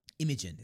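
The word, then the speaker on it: Imagin: Imogen is what the monsters are called. – Oh, okay. I thought you just stuttered over it, but you just said it right. – Yeah, Imogen.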